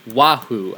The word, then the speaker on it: Wahoo: Oh man, Chief Yoo-hoo? Are you all right? I think I probably mispronounced it. Wahoo. I think that's how you pronounce it.